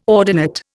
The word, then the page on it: ordinate: Indian